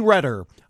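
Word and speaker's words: Reder: Tommy Redder, I believe is how you say this, R-E-D-E-R. I always want to default to Reader, but it's got to be Redder, right?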